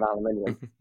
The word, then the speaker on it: aluminium: Made out of aluminium. – Sorry, you're the first person on this podcast to pronounce aluminum in the UK way, and it's just like, oh, right, different continent, different English. Got it.